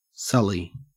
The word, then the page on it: sully: Australian English